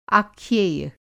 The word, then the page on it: आख्येय: Hindi